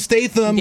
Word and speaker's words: Statham: Starring Jason Statham.